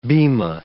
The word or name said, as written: bimah